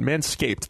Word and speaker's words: Manscaped: Manscaped, that's a D at the end.